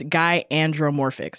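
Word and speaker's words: gynandromorphic: Guy andropomorphic. – Thank you. – Oh, no, there's no pro. It's guy andromorphic. Sorry. – Charlie is a guy andromorphic green bean stick.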